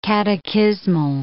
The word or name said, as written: catechismal